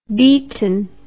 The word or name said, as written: Beeton